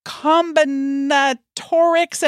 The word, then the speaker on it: combinatorics: Yeah, you got it. Combinatorics. It's like combinations almost.